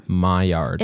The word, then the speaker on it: Maillard: Maillard. – It's spelled almost like Mallard duck. It's Maillard. I think it's French, Maillard.